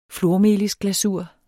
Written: flormelisglasur

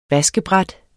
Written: vaskebræt

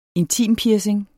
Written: intimpiercing

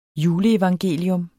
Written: juleevangelium

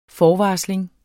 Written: forvarsling

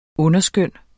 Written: underskøn

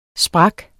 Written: sprukken

